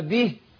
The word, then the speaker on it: به: بس عايز انبه على نقطة للمشاهدين، كلمة به، لأن البعض بينطقها خطأ. أقول فإن أصابه خير اطمأن به، به أبان الكسر اللي في الباء مع ترقيق الهاء.